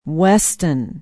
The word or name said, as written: weston